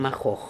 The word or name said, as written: makhokh